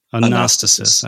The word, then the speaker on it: Anastasis: Do you pronounce it Anastasis or Anastasis? – Anastasis. – I was noticing the little accent mark over that second A.